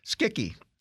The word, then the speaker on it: Schicchi: No, it's a hard C. Schicchi. It's Gianni Schicchi. We're going to do that. I looked that up. That CH is a hard K.